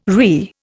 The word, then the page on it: RE: Indian